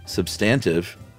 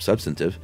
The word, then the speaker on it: substantive: Wade episode, Chuck at first pronounced substantive with the accent on the second syllable and instead of substantive, and it made such an impression on him that he made fun of himself later in the episode.